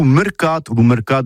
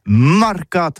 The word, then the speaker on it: marcat: Eh bien, disons à tous, mais le mercat de l'Uni ou le mercat des rebelles, mais en gascogne toulousano, c'est prononcé sur les marcats, avec un A marcat.